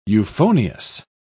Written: euphonious

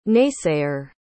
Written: naysayer

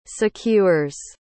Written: secures

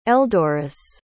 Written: eldoris

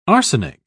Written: arsenic

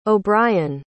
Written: O'Brian

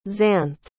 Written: zanthe

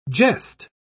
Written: jest

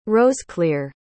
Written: roseclere

